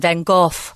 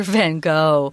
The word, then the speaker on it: Van Gogh: That's the Dutch pronunciation, Van Gogh. – Okay. – Van Gogh is his name. But if you're talking to a native English speaker, you'll always hear Van Gogh.